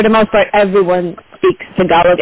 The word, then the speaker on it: Tagalog: Well, thank you so much for correcting me on that pronunciation, Tagalog. So, because I've always heard them say, because I always have watched boxing telecasts where they featured Filipino fighters and they had the interpreter, and they always pronounced it how I pronounced it before. But thank you for giving us the correct pronunciation, which is Tagalog.